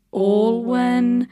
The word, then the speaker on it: Alwyn: They had a big storm coming this weekend when it was announced that Taylor Swift and her boyfriend, I always thought his name was pronounced Joe Alwyn. – Yes. – But now I've been hearing people pronounce it Alwyn. – Like Baldwin.